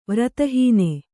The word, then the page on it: ವ್ರತಹಾನಿ: Kannada